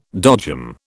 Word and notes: dodgem: British English

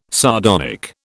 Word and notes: sardonic: British English

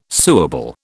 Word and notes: suable: British English

